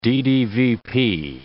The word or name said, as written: DDVP